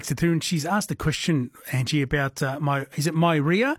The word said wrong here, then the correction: Meyer: So maybe a Meyer lemon, so M-E-Y-E-R. – That's it, yes. Excuse my bad pronunciation of it. – Oh, good. It's it, yeah, Meyer lemon.